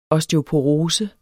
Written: osteoporose

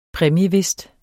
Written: præmiewhist

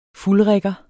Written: fuldrigger

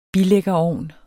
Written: bilæggerovn

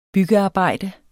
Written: byggearbejde

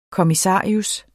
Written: kommissarius

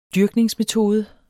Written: dyrkningsmetode

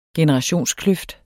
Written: generationskløft

